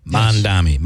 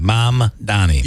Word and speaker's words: Mamdani: Tufti, now how does, and by the way, it does seem that Mamdani is, I always say it wrong. Is it Mamdani? Mamdani. Yes. Mamdani.